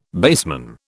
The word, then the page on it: baseman: British English